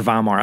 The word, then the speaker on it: Weimar: The Weimar Republic, or Weimar, I don't know how you pronounce it. – I'll save it. It's with the W, but I think you say it with a V.